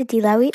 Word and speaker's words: Edelawit: My name is Adelawi Okwan, and Adelawi is spelled E-D-E-L-A-W-I-T.